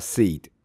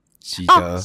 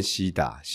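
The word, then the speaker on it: Sid: 然后我就是在一个卡通，嗯，就是《冰原历险记》，嗯，它的它其实正确发音叫 Seed，喜德，哦、喜德，对。那因为这边太多人哈，对的朋友跟我讲，就是会念到变西打，西打，西打，就变成叫西打。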